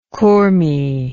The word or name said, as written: Kurmi